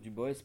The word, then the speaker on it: Du Bois: Je prononce Du Bois parce qu'il euh, il a insisté pour qu'on le prononce comme ça.